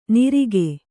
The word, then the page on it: ನಿರಿಗೆ: nirige